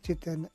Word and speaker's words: Jiten: Since I already spelled Jiten and Patel, that's a given.